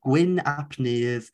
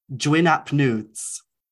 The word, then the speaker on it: Gwyn ap Nudd: but they pronounced his name Dwyn Ap Nudes.